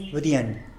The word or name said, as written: bïdien